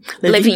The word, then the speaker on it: Lewin: Então, e aí voltando aqui para os fatos, tem um, um, estudo de 1940 do Kurt Le Lewin, eu acho que assim pronuncia, o Levin. – Levin. Levin. É,